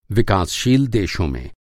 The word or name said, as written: विकासशील